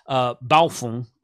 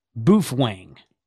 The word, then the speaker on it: Baofeng: Baofeng, I'm going to say it like that. Not a Rubicon, says Boofwang, which I thought that was funny.